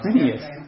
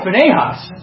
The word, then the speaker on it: Phinehas: Who's Phineas? – He drove a spear through an Israelite man. – Oh, Phinehas. Oh, I'm sorry. I think he said, I just pronounced it different than you. So, yes, Phinehas.